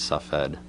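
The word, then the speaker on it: Tzfat: The spelling of it that you see in most books in English is it's spelled like it's Safed, which is based on the Arabic pronunciation of the name. The Hebrew pronunciation is Sfat.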